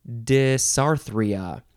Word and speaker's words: dysarthria: Dysarthria. D-Y-S-A-R-T-H-R-I-A.